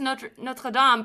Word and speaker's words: Notre Dame: Notre Dame. – I got yelled at there too, because in French it's Notre Dame, but then I'm like halfway through it, I'm like, I don't want to say it in French.